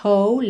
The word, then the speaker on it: whole: Make it simple to last your whole life long. Whole. Essa palavra inteira, inteiro, W-H-O-L-E, tem a pronúncia whole. Whole. Ou seja, na verdade, você ignora esse W inicial.